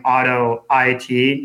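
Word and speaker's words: AutoIt: Auto IT, per forum pronounced auto IT. He said, I suspect as in automate IT. I'm not sure what that means. I'm sure you probably do. – Yeah, I think he's correcting my pronunciation, and I think he's probably right, right? It's probably supposed to be, I probably should be saying auto IT, not auto it.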